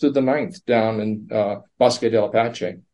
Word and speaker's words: Bosque del Apache: The Bosque del Apache National Wildlife Refuge? – Yeah.